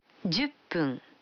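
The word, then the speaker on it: Дзюппун (十分): А 10 минут – джупун. Вы заметили, что когда фун добавляется к 10, дю, то произносится пун. – Послушайте и повторите каждую фразу. 5 минут. – Гофун. – 10 минут. – Дзюппун.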